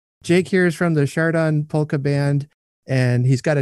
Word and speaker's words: Chardon: I have the opportunity today to uh, interview Jake from the uh, Chardon Polka Band.